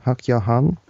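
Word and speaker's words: Hak Ja Han: And I understand that in 1960, when Reverend Moon married Hak Ja-Han, is that the correct pronunciation? Hak Ja-Han? – That's approximately right.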